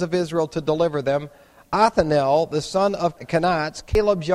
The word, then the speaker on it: Othniel: Othniel is how you pronounce that.